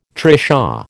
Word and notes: trishaw: American English

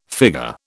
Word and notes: figure: British English

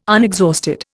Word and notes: unexhausted: Indian

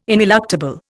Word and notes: ineluctable: Indian

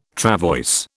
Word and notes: travois: British English

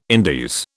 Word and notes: Indies: British English

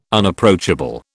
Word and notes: Unapproachable: British English

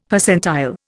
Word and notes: Percentile: Indian

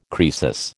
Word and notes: Croesus: American English

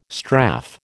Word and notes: strafe: American English

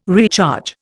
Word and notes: recharge: Indian

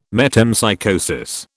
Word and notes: metempsychosis: British English

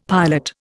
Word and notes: pilot: Indian

